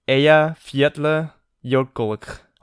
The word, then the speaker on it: Eyjafjallajökull: So it's Eyjafjallajökull. Like, you have to do a k at the end of it. – Maybe that's the O. – So it's Eyjafjallajökull.